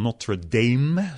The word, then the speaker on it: Notre Dame: We eh, worden net even spreken tijdens een eh, commencement-toespraak, een, een afstudeertoespraak op de Universiteit van Notre Dame. Zo spreek je dat in het Amerikaans uit, eh, in Indiana.